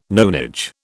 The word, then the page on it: nonage: British English